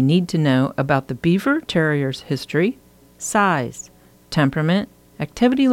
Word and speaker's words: Biewer: They named the breed the German Yorkshire Terrier, but their veterinarian suggested calling it after themselves, the Biewer Yorkshire Terrier, pronounced just like the well known rodent, the beaver. It's spelled B I E W E R.